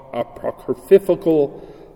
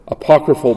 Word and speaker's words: apocryphal: So if you looked at the Old Testament, which our Lord used, it would have included those so-called apocryphal, I just mispronounced that horribly, apocryphal books.